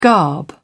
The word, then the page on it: garb: British English